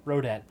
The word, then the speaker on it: Rodat: Robert Radot? Radot? How do you say that name? – Rodat.